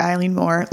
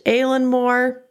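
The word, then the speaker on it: Eilean Mor: In this episode, we are going to talk about the disappearance of the Eileen Moore Lighthouse Keepers. – Also possibly pronounced Aileen Moore, but we're going to go with the very English pronunciation because that's what we've heard the most.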